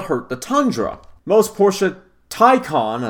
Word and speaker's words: Taycan: Part of me just wants to say Taycan, like I've been saying, to spite Porsche, because it's spelled, it's Taycan, Taycan. But apparently, Porsche says Taycan, like Thai food. And then con, like con artist.